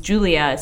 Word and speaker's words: Julya: Yes, I was born in July, and my name is Julia, spelled with a Y, which essentially is Julia, which is very obnoxious to me.